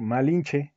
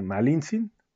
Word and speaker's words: Malintzin: Y los españoles ya por así, también eh, por maña o por lo que fuese, le empezaron a decir Malinche en vez de Malintzin, digamos con esa eh, pronunciación que, sería más correcta de, los habitantes de ese tiempo, como que más pronunciándolo como una che, dándole ese más intonación Malitzin pues dijeron los españoles, pues Malinche, ¿no?